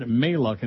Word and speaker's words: Maluk: Have a great show, Neil God, respectively Lord Maluk, and he tells me how to pronounce it. M-A-L-U-K, Maluk.